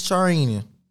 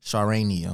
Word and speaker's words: Charania: According to a report by Shams Charania of the Athletic, I believe I said his name wrong, Charania, I'm sorry.